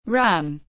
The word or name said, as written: Ran